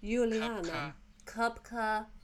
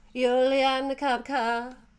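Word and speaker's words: Juliane Koepcke: Juliane. – Kupka. – Juliano. – Juliane Kupka.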